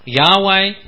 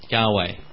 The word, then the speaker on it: Yahweh: We get Yahweh because mainly because there's a couple of early Christian writers, when they translate it, they translate with that kind of sound, Yahweh. So we think probably that's the way it's pronounced.